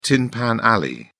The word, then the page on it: tin pan alley: British English